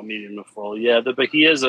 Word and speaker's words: Bahia: Is it Bahia? – Bahia, yeah. – The Brazilian, Bahia. Yep. – Bahia, Brazil. It is a 6x50 Toro featuring a Brazilian Maduro wrapper, Nicaraguan binder, and Nicaraguan filler.